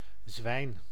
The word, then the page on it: cochon: [kɔ.ʃɔ̃]; France; French